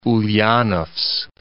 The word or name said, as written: Ulyanovsk